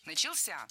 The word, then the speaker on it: начался: Начался первая ошибка. В этом глаголе ударным должен быть последний слог, возвратная часть «ся», которая, кстати, часто находится под ударением. Начался.